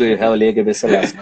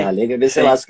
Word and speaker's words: Haile Gebrselassie: Então eu aprendi a falar Haile Gebrselassie com o etíope falando Haile Gebrselassie. Ah, então é Haile Gebrselassie que fala.